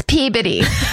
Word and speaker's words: Peabody: They go to IHOP in Peabody, Massachusetts, which I'm sure will have listeners that are like, it's Peabody or some fucking Massachusetts loves to not pronounce anything the way it looks. – Silent. It's just it's, body.